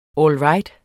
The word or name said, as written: all right